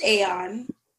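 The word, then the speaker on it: A0N: So this guy's name is Aeon, and it's spelled A space, the number 0 space, the letter N, but it's pronounced Aeon.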